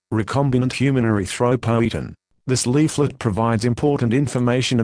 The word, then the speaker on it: erythropoietin: Epotin beta is also known as recombinant human erythropoietin, pronounced erythropoietin.